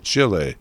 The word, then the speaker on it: Chile: And from there, we move across the southern hemisphere to the nation of Chile, as they pronounce it in Chile, the Free Republic of Chile, where Michelle Bachelet is head of state.